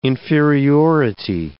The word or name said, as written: inferiority